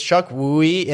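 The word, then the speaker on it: Chukwudi: It's Chuck Wooey, and then and you help again.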